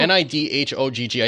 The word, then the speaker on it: Nidhogg: N I D H O G G. I still don't know how to pronounce it. – I think it's Nidhogg. – Is it Nidhogg? – Yeah.